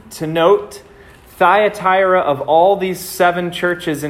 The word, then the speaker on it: Thyatira: Well, tonight we're looking into Jesus' letter to the church in Thyatira, is how you say it.